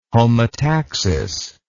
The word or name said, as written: homotaxis